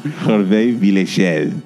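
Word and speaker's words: Hervé Villechaize: Hervé Villechel.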